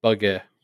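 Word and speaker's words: Bugge: I'd been pronouncing it like more like bug in my head, but bugger.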